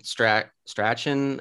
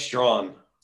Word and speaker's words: Strachan: And then, yeah, the kid Strachan is. – Well, it's like Strachan, but like I read somewhere, it's like pronounced Strong.